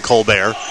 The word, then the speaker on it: Colbert: You know, with the success of Stephen Colbert on Comedy Central and now on the CBS Late Show, I can't look at a name that's spelled the same as his and think it's anything was anything other than Colbert. But of course, Colbert is the traditional pronunciation, and that was the pronunciation of his name. – A little French emphasis there.